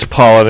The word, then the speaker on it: Pollan: It is Pollan. It's not spelled like plant. It's P-O-L-L-A-N.